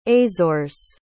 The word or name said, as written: azores